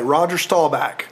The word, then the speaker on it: Roger Staubach: I will say, to finish the smoker, they named the smoker after famous Dallas Cowboys quarterback, and in Texas, you only say his name one way, Roger Staubach.